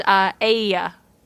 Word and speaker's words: Aeaea: Spelled A-E-A-E-A. – How do you pronounce that? – Pronounced Aya.